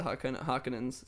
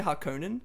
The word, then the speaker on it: Harkonnen: So the Atreides in the story, the good guys, the Harkonnens. It's pronounced. I used to say Harkonnen, but I believe it's actually pronounced Harkonnen, which it doesn't sound as menacing.